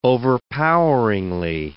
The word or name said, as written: overpoweringly